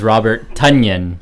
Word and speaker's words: Robert Tonyan: Robert Tonya. – All right, that one's on Luca. Luca wins the round. – Let's go! Week. – The correct pronunciation is Robert Tonyan.